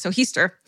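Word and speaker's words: Heaster: Aunt Zona Hester, pronounced like Easter with an H, Hester, was living with her mother, Mary Jane, in Greenbrier County, West Virginia, when she met a man named Ursmus Stribling Trout Shoe.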